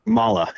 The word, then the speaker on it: Mahle: Mahle is the proper pronunciation.